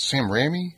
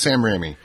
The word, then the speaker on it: Raimi: There's so many twists because of the director Sam, and I always butcher his name. It's Sam Raimi. – Yep. – Raimi. So I think it's Sam Raimi. The correct pronunciation.